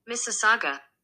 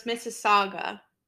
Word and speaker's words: Mississauga: Mississauga. – We're not that close. You're pretty close. – All right, so it's Mississauga, Ontario.